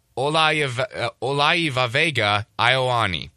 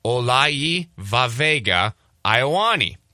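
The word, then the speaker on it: Olaivavega Ioane: You have to overemphasize every vowel that you see. So, Olaiva Vega Ioani.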